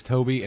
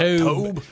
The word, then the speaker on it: Tobe: I feel like his name is Toby, and I've just been saying it douchey all these years. It's spelled T-O-B-E. – Rather than like a Y or whatever. – Tobe. – Okay. Tobe. – What up, Tobe? Crockett and Tobe. Just hard B at the end, Tobe.